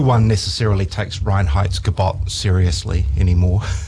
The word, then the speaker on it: Reinheitsgebot: And they even say they're adhering to the Reinheitsgebot. – So I was going to put you on the spot and say, what's that law they have over there? – Yeah. – And you pronounced it perfectly.